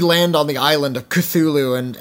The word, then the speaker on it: Cthulhu: We pronounce it Cthulhu, although in the story it's like... It's like this weird guttural noise.